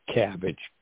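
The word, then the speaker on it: CABG: a procedure called CABBAGE, coronary artery bypass graft. I said it's pronounced like the vegetable in coleslaw.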